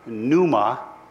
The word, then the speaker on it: pneuma: The actual word in the New Testament for spirit here is pneuma, It's spelled P-N-E-U-M-A. P-N-E-U-M-A.